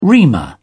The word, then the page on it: reamer: British English